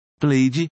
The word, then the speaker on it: played: Clock, relógio, clock, e não clock, bet, apostar, bet, e não bet, played, jogou, joguei, played, o a tem som de i, playing, jogando, playing as diferentes pronúncias do h.